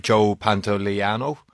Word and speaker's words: Joe Pantoliano: You have Joe Pantoliano. There, I said it right. Yeah. I got a thumbs up from that.